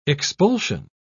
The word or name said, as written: expulsion